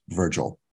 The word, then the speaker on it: Vrgl: And that's just four letters, V-R-G-L, but pronounced Virgil.